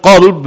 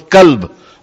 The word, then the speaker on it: qalb: But when you pronounce the word qalb with a kaf, it becomes kalb. And kalb means dog.